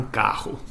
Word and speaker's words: carro: It's almost pronounced kind of like an H type sound, like un carro. Eu vou comprar um carro.